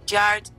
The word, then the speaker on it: Hard: Se dice más o menos. Claro. Algo como Yard to Say I'm Sorry.